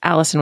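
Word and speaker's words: Alison: Because My name is spelled Allison with one L, so I'm a stickler for spelling names correctly.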